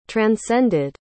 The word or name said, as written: transcended